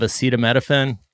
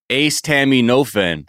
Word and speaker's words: acetaminophen: Wait, that's how you say it? What is it? – Acetaminophen? – I love Kirkland brand. – I thought it was Ace Tammy Nofen.